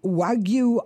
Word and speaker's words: Wagyu: And this is the Wagyu of Duck.